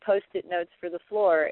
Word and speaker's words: Flor: It's called Floor, F-L-O-R, sort of like the first syllable of uh-huh. – Florida.